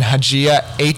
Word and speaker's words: Haxhija: Yeah, so Denis with one N and then Haxhija, Haxhija.